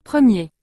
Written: 1er

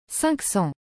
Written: 500

